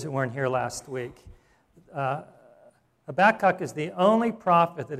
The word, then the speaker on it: Habakkuk: But it is Habakkuk is how you pronounce it. And you can see that here. It is. You listen to it. It's Habakkuk.